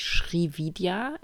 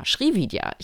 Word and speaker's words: Srividya: Die Linie nennt sich Srividya, S-R-I, neues Wort. Oh, ich kann super gut buchstabieren, ist übrigens auch Anteil meines Vaters. Äh, V-I-D YA, Srividya, ich glaube, so schreibt man es.